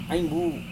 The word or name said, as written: ainggu